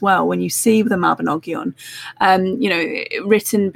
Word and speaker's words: Mabinogion: but the Mabinogi, is that how you say that? Mabinogian? – The Mabinogion. Well,